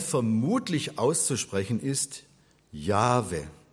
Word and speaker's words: Jahwe: Es gibt auch die Betonung auf der letzten Silbe, Yahweh, das machen die orientalischen Juden, wenn sie den Namen aussprechen täten. Also Yahweh ist mehr die orientalische Betonung, Jahwe ist mehr die europäische. Was definitiv falsch ist und was ich vielen Theologen schon gesagt habe, ist Jahwe. Es gibt kein Ch in diesem Namen. Es ist Jahwe.